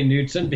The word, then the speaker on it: Knudsen: It's spelled K-N-U-D-S-E-N. I often say Knudsen because it seems like the logical way to pronounce it, but right.